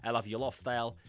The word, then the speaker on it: I Love Your Lifestyle: I Love Your Lifestyle, I love your lifestyle. This is the correct pronunciation.